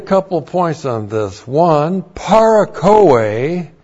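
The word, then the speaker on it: Parakoe (παρακοή): And then with the first Adam is the word parakoe. P-A-R-A-K-O. Long E. Parakoe.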